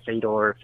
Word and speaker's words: Fedor: Probably the greatest of modern times is Fedor, or how do you say it? Fedor. It's F-E-D-O-R.